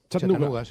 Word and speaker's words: Chattanooga: ¿Se pronuncia así en Chattanooga? – Chattanooga, sí. Chattanooga.